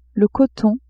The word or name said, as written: coton